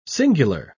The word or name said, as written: singular